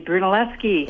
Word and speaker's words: Brunelleschi: That would be Brunelleschi. – Yes. I'm so glad you call in, because then you say it right, and then I know how to pronounce it after this.